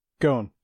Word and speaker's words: Ghosn: The big news is Carlos, how are we pronouncing it? Ghosn? – Ghosn. Yeah.